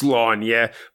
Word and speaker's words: Sláine: Sláine is, of course, pronounced Slanya, but I'm not doing that.